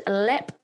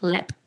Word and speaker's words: Leap: Well, it's called Lep Castle. So it's spelt Leap, L E A P, but it's pronounced Lep. Okay.